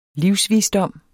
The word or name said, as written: livsvisdom